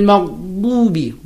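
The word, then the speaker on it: Maghdubi (مغضوبِ): It's not maghdubi. Maghdubi. – Maghdubi. – Maghdubi. Alayhim. Alayhim. Alayhim. – Him. – Walad. And then door coming. – Yeah. – Ghayril maghdubi alayhim walad dallin.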